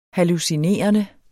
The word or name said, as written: hallucinerende